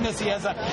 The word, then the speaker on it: Nicieza: It's Fabian Nicieza, he read this too. – And you know what? Thank you. You just pronounced his name, and it's like the first time I've ever heard somebody actually who knows him pronounce his name.